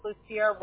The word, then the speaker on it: Lucia: So, your first name, it is spelled L-U-C-I-A, correct? – Correct. – Okay, and do you have a middle initial? – It's just Lucia Lucia Rodriguez.